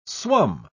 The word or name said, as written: swum